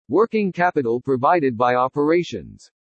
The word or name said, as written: WORKING CAPITAL PROVIDED BY OPERATIONS